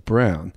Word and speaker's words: Braun: It's B-R-A-U-N, but they said it's pronounced Brown.